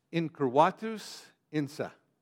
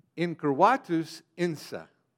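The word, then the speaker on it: incurvatus in se: It's pronounced this way. Incurvatus insa. Got it? Let me say it again. Incurvatus insa. Now let's say it together. You ready? Incurvatus insa. Now, living in Texas, we would probably pronounce it incurvatus insa.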